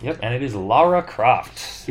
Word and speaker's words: Lara Croft: It is one of the most mispronounced names by Americans. Throwing in that O or throwing in a U for some reason and calling her Laura, but it is Lara.